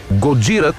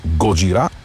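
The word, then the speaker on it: Gojira: Годжира, това е японската дума за Годзила. Знаем, че групата започва именно по това име. Позволявам си да произнасям с френското ударение на последната сеичка – Годжира.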